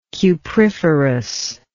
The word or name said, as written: cupriferous